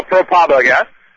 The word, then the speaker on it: ProPublica: Lui in americano pronunciava ProPublica.